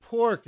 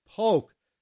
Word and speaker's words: Polk: And then the Edwin Hawkins Singers with Oh Happy Day, and wrapped it up with Poke Salad Annie by Tony Joe White. And it's not pork, it's poke. P-O-L-K Salad Annie. Like Polk Street here in San Francisco. P-O-L-K. Poke Salad Annie.